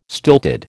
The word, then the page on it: stilted: American English